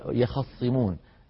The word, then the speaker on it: يخصمون: وكذلك كلمة في سورة ياسين يخصمون، يخصمون يخصمون أصلها يختصمون، يختصمون، فالتاء أبدلت صادا وسكنت ثم أضغمت الصاد في الصاد وأصلها يختصمون صارت يخصمون، يخصمون،